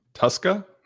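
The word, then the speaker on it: Tuszka: T22 says it's pronounced Tuska, like a Tuscan Raider without the N.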